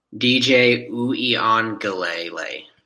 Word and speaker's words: DJ Uiagalelei: DJ Uiagalelei.